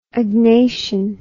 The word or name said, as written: agnation